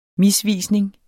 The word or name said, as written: misvisning